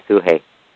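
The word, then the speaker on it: Sugehit: Am I saying her name right, Sugahit? – Yeah, Suhei. – Suhei, okay. They spelled it S-U-G-E-H-I-T. I thought it was spelled differently, but... – Yeah, they spell it like seven different ways. – Okay, so the way they spelled it, I thought her name was Suge Hit. – Yeah, just say Suhei.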